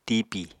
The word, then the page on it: tipi: Apatani